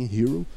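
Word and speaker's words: Herro: É, foi, era até uma dúvida nossa como pronunciar o nome de Tyler Hero, fui ver como que os, os narradores americanos pronunciavam. E é assim, Hero, embora ele tenha dois R's no, no nome dele.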